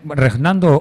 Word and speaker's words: Reinaldo: Reinaldo, como lo pronuncia él. Sí.